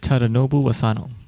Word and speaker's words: Tadanobu Asano: I'm not going to be able to pronounce Raiden's name, but it's Tadanobu Asano.